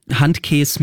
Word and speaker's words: Handkäse: Handkäse mit Musik. – Oh, Handkäse. Oh, so this sour milk cheese is called Handkäse, and it's with onions, oil, and cumin seeds, and it's called Handkäse with music because some people say...